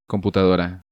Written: Computadora

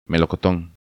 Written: Melocoton

